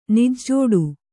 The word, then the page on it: ನಿಜ್ಜೋಡು: Kannada